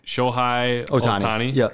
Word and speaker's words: Shohei Ohtani: It's, I don't know how to say his name, Shohei Ohtani.